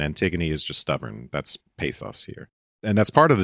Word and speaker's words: pathos: It's in the ancient Greek, it's pathos. And the accent would be on the path.